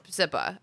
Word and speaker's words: Hepzibah: Hepzibah.